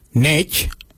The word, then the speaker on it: ngeidj: For I, we say NICH. NICH, it's spelled NGEIDJ.